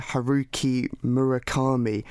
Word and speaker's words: Haruki Murakami: It's now time to hear about your second book, Dr. John, which is Hear the Wind Sing by Haruki Murakami. I hope I pronounced that correctly. – Well done.